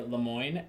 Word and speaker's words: Lemoine: I am on Instagram at Colin with one L, C-O-L-I-N underscore Lemoyne, L-E-M-O-I-N-E.